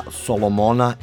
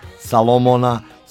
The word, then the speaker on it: Solomon: Svi ti izrazi koji upućuju na mudrost, domišljatost i pravednost vezani su za ime jevrejskog kralja Solomona ili Salomona, Salomona, Salomuna, već prema tome kako se i gde kod nas izgovara i piše.